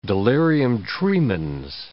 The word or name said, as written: delirium tremens